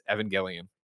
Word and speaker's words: Evangelion: Lind announced the news via his Twitter in a tongue-in-cheek video riffing on the next episode bookends of Neon Genesis Evangelion. I screwed up, right? Evangelion. What do you nerds? How do you pronounce it? – You know, you got it the first time. – Evangelion.